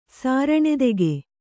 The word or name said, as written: ಸಾರಣೆದೆಗೆ